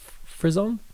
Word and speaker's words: Frison: What do you say, frison? – I found it's easiest to just go frison rhymes with prison.